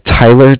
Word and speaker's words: Tyler: My name is Tyler, T-Y-L-E-R, and I'm calling from Bank of Cardiff. It's spelled C-A-R-D-I-F-F, as in Frank, Frank.